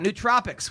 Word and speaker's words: nootropics: Chimera Coffee, it's got nootropics, which are brain vitamins. – Nootropics. – Feed that brain. – Nootropics. – I've been saying it wrong for the past.